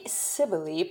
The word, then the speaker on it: Cybele: Now, Cybele is probably not the way that the Romans actually pronounced Cybele. They probably pronounced it with a K.